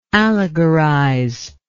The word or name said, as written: allegorizer